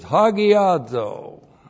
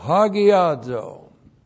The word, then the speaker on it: hagiazo: Sanctification means to be set apart, and the Greek word is hagiazo. H-A-G-I-A-Z, long O, hagiazo.